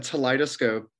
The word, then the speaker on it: Teleidoscope: So a Teleidoscope is similar to the word that it rhymes with, which I think people are more familiar with, a kaleidoscope.